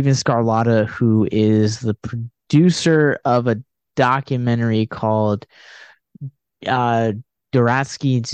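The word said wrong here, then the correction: Jodorowsky: Dune. I probably mispronounced that. – I know Yodorovsky's Dune.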